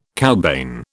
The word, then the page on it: cowbane: British English